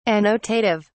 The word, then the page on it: annotative: British English